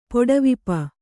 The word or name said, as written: ಪುಡವಿಪ